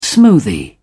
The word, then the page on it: smoothie: British English